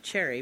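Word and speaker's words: Cherryh: It's CJ, I think it's pronounced Cherry, but it's got an H on the end, so it's basically CJ Cherry.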